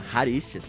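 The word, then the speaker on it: Charisteas: Então, Caricias lá, que a gente tentou pra caralho pronunciar o nome, se chama Haricias.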